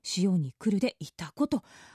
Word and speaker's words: 潮来: 漢字で潮干狩りの潮に来ると書いていたこと読みます